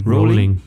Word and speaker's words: Rowling: Rowling.